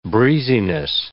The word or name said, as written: breeziness